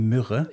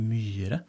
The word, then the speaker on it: Myhre: Myhre. Nå har jeg lært meg å uttale etternavnet riktig også, ellers blir du sur. fordi jeg, pleier å si som mange tyskere Wenche Myrre.